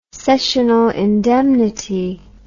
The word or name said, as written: sessional indemnity